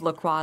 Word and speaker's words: LaCroix: Well, I was LaCroix until the company came out and said, we pronounce it LaCroix. – And you're like, no, you're wrong. It's actually LaCroix.